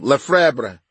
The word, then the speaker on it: Lefebvre: Lefebvre, as in, pronounce it L-E-F-E-B minus the F-R-V-E part. – Instead of the B, I'm more pronounced the B.